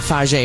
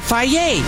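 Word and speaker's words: Fage: And that's how you pronounce it, not Faye, but Faye.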